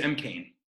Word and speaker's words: Cain: I thought you really nailed that atmospheric tone of like, you know, James McCain, or am I saying that right? James? – Huh? – Yeah. – James M. Cain.